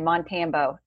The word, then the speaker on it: Montambo: Like the first couple of episodes we did together, everybody always stumbles over my last name, Montambo. It's like Rambo.